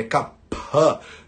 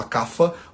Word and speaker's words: Kapha: Muita gente fala Kapha, porque é assim que a gente pronuncia, por exemplo, em Gujarati, em Hindi, né? Na pronúncia do sânscrito, de verdade, é Kapha ha, né, com P, com Mahaprana, né, com bastante ar saindo, mas no Hindi e no Gujarati, que por exemplo eles pronunciam F, né, como se fosse um F né, em português, então você pode falar kafa ou você pode falar kapha, né, depende de, de, de qual é a tua orientação, né, qual é a base linguística que você está é, tá usando.